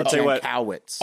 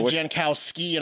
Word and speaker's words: Jankowicz: Nina Jankowicz. – Oh, I thought you said Jankowski, and I was going to be like, I really don't want to go back on the Polish people thing.